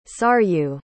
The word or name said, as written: saryu